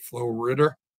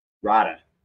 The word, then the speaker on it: Flo Rida: Some people pronounce it Flo Ritter. Or Rida? How do you say it? – Rida. – Rida. Like with an H.